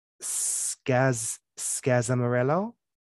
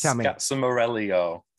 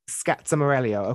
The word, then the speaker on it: Scazzamarello: the Scazzamarello. Did I say that right? – Very close. It's Scazzamarello. – Scazzamarello. Okay.